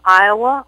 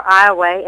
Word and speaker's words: Iowa: I'm from the small town of Iowa, or Iowa, as we pronounce it, Louisiana.